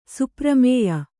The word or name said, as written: ಸುಪ್ರಮೇಯ